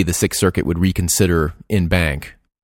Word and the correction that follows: en banc: or in banc, as Paul said. That was one other thing that listener Paul commented on, Joe, was pronunciation there. Yeah. – Yes. – And really en banc, I guess. – Yeah. – Right. Or as you say, en banc.